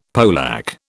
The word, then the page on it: polack: British English